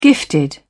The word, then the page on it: gifted: British English